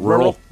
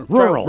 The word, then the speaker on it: rural: Rural. – Rural. – Rural. – Rural. – Rural. – It's like a Merle. – It's like Merlin.